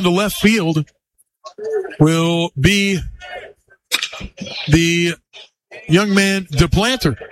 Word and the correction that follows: DePlantier: Actually, DePlantier is the proper pronunciation.